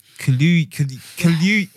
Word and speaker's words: Kaluuya: So, Daniel Kaluuya. Have I said his name right? – Kaluuya.